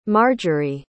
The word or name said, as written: marjorie